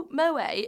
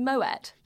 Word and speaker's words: Moët: Also, did you know Moët is actually pronounced Moet? – Yes. – Is it? – Yeah, because the guy's Dutch.